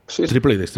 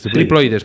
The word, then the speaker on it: triploides: al final son truchas duploides. Sí, creo que lo he dicho bien, ¿no? – triploides, triploides, perdona.